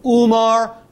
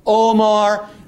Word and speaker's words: Umar: Sometimes you see his name spelled Umar, sometimes Omar. It's the same word in Arabic, just a dialectical difference in how the vowel is pronounced.